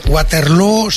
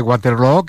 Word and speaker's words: Waterloo: Pues no lo es, desde luego, pero Abba ha hecho mucho daño en la pronunciación de Waterloo, que se pronuncia igual que Feijóo.